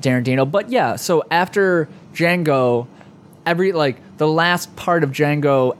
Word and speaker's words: Django: You put the D on the beginning. I think it's a soft J. – Django. – I think it's just Django.